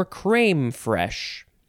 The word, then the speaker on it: crème fraîche: The word is creme fraiche or cream fraiche or crème fraîche. Same first word. Second word is F-R-A-I-C-H-E. And you can, if you want, put an accent on the I, which is, again, that little carrot hat accent. The sound it makes is ee.